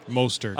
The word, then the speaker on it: Mostert: Is it Mostert? – Mostert. – I always think of a cupcake or like some dessert. – I do that anyway, but it has nothing to do with Mostert.